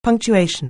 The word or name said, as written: punctuation